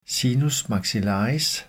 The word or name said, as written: sinus maxillaris